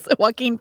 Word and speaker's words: Joaquin: Is it Joaquin? – Yes. Joaquin Phoenix.